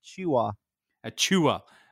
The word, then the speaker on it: Achiuwa: Achiuwa. – Bless you. Precious Achiuwa